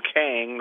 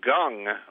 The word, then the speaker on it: Kang: I mean, we were told that you pronounce it like Gong, like the Gong Show.